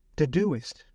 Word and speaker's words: Todoist: My friends, we also can look at many, many resources like apps and tools, the You can spell that. Taoist, a task management app. It's spelled T-O-D-O-I-S-T. Todoist. Okay, I get it. Todoist.